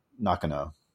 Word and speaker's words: Nakano: Mahashi Hamauzu and uh, Junya Nakano. Nakano. – Yep, there. You, I think you got it.